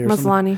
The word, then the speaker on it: Maslany: Tatiana Maslany, right? – Maslany.